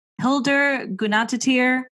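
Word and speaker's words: Hildur Guðnadóttir: Hildur Gunnarsdottir.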